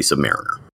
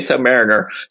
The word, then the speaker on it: Submariner: When I was a little kid, I thought it was Submariner, but I am pretty sure it's supposed to be Submariner. – Yeah, he has not appeared in the MCU for various legal reasons, so we don't have that to fall back on in terms of pronunciation, but I'm going to say Submariner.